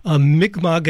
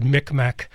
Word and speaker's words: Mi'kmaq: And I'd like to just say, before we go to another fundraising break in a second here, Mi'kmaq, as you pronounce it, I just want to say, if people are interested in looking that up, it's generally spelled M-I-apostrophe-K-M-A-Q. So your pronunciation, obviously, is probably more faithful than maybe people would. I always pronounce it Mi'kmaq, which is like the way it looks. But You can do Mi'kmaq, M-I-C-M-A-C, and uh-huh.